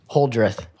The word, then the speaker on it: Holdrieth: Holdrieth.